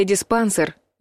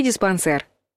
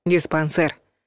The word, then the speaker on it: диспансер: Тот случай, когда в русском языке сохранилось оригинальное ударение. А во французском, как мы помним, ударение в словах падает на последний слог. Так что вариант один — диспансер. Кстати, произносится твердо, через звук «э». Но пишут «е».